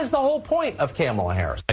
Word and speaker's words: Kamala: It kind of looks like Kamala or Kamala, Kamala, and it's Kamala.